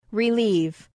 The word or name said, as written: relieve